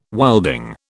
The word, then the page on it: wilding: British English